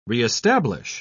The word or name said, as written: reestablish